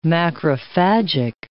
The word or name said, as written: macrophagic